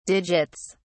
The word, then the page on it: digits: British English